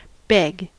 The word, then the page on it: beg: American English